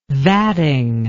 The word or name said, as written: vatting